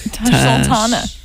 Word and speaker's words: Tash Sultana: All right, that was Jungle by Tosh Sultana. – Tash Sultana.